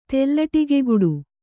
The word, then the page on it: ತೆಲ್ಲಟಿಗೆಗುಡು: kannada